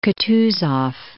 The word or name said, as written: Kutuzov